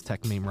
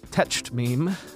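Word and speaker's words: Techmeme: Just ask Siri to play the podcast Tech Meme Ride Home. Siri pronounces it Tetched Meme, but does queue up the latest episode.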